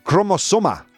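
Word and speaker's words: cromosoma: Um, chromosona. Chromosoma. So, all right, I spelled. I said it wrong. Natch. I said it wrong chromosoma.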